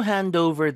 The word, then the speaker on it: hand over: D가 붙어서 Dover 이런 식으로 발음이 되겠죠. Hand over. 이렇게. 자 예문 드려볼게요. 이 책 좀 그 친구한테 건네줄 수 있겠니? 의문문. Can you hand over this book to him?